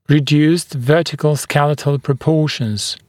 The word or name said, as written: reduced vertical skeletal proportions